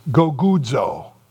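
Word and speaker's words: gogudzo (γογγύζω): This word in the original language, it's kind of fun to pronounce. It's gogudzo. Gogudzo.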